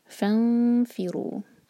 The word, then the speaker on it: فانفروا: Next है فانفروا ثبات، یہ سورة النساء آیہ نمبر 71 سے۔ فانفروا، فا کے بعد جو الف ہے یہ حمزۃ الوصل، تو اس کو اگنور کریں